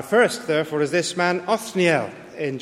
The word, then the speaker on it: Othniel: I tried to find out what his name means, but nobody seems to know what his name means, Othniel, but I'm quite sure that's how it's pronounced,